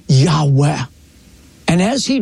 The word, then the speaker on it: Yahweh: It's known as the Tetragrammaton, and most Semitic scholars believe it's pronounced a breathy Yahweh.